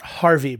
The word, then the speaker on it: Harvey: Right his, his is pronounced Harvey, by the way. It's Harvey. – What did I say? – Harvey. I said Harvey? Harvey. – Yeah, well, what are we talking about? Harvey. I said Harvey.